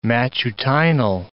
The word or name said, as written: matutinal